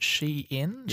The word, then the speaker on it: Shein: Shein, yep.